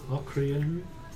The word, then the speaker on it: Lokrian: L-O-K-R-I-A-N. I'm assuming that's where I'm from as well. – L-O-K-R-I-A-N. Locrian.